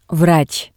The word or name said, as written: vrats